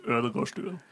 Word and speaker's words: Ødegårdstuen: So I'm Fredrik Ødegårdstuen.